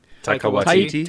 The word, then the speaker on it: Taika Waititi: Takiya Kapakawatekwati. – Taika Waititi.